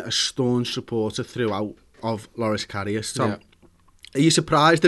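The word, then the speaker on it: Loris Karius: Lloris Karius, I keep pronouncing this name wrong, it's Lloris Karius, feels let down by Jurgen Klopp because we've signed a boss goalkeeper.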